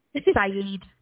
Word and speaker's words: Syed: It's Saeed, S-Y-E-D. – E-D. – Sorry, S-Y-E-D. – Yep. What, how do you pronounce that? – Saeed.